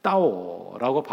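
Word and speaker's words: 따오 (到): 이 중국어의 뒤집다라고 하는 뜻을 가진 단어와 도착하다는 이 중국어가 따오라고 발음이 같아서 이 복자를 거꾸로 붙여놓게 되면 복이 따오, 도착했어요, 복이 왔어요 라고 하는 의미가 된다는 겁니다.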